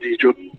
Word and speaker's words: Dijo: And am I pronouncing this correctly? Dijo. Dijo. – Yeah, that's perfect. Dijo.